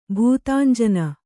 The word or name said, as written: ಭೂತಾಂಜನ